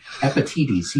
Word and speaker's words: Epictetus: It was written a long time ago by a person by the name of Epictetus in 1200 BC. So we've got a lot to learn yet. – I can't even pronounce that name. Could you say that again? – Epictetus.